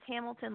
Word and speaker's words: Hamilton: But my name is spelled KrystelleE, and the last name is Hamilton, like the city, even though I'm so far away.